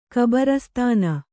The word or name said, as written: ಕಬರಸ್ತಾನ